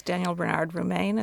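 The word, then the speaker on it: Daniel Bernard Roumain: The composer is Daniel Bernard Romain. Is that how you say his name? – Yeah. That's right.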